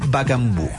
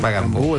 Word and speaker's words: Bakambu: Bakambu. – Bakambu, el 9. – Bakambu, eso.